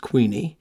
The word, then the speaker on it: Quiney: And it was there one October day in 1598, October 26th to be exact, that a Richard Queeny, it's spelled Quiney but pronounced Queeny, wrote a letter to William Shakespeare.